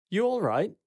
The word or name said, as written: You alright?